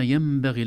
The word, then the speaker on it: ينبغي: وما ينبغي لهم، في الآية 211، وما ينبغي لهم، حرف الميم فوق النون، وهذا يدل على أن النون انتقل مخرجها إلى مخرج الميم، لا تنطق نون أبدا، لا نقول وما ينبغي، لا نقول هكذا أبدا، من نقول وما ينبغي، إذن النون الساكنة تنقلب إلى ميم إذا جاء بعدها حرف الباء، ويسمى هذا الحكم حكم القلب أو أحيانا يسمونه الإقلاب.